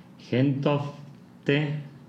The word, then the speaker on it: Gentofte: Y antes de que continúes, el lugar de origen de Lars Ulrich es Gentofte, Dinamarca lo pronunciaste bien.